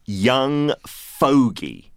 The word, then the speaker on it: fogey: young fogey.